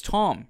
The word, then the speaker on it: Tom: And I realized, I said to the missus, I'm like, oh, everyone just calls me Don all the time. And which makes sense. I mean, obviously. She goes, yeah, well, you never actually say your name ever. So it's Tom, if anyone was wondering.